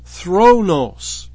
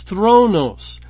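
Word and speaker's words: thronos (θρόνος): That word is thronos. That's how I would pronounce it. The Greek word is thronos and it's translated oftentimes, more times than it's translated as seat, it's translated as throne.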